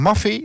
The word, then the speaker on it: Maffay: Want we gaan er namelijk uit met Peter Maffay. Zeg ik dat goed? Ja, dat zeg ik goed.